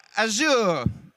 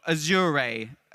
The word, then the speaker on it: Azure: For messaging, for crypto, and of course, for Azure, or Azure, as some people like to pronounce it.